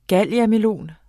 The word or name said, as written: galiamelon